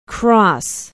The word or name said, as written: Cross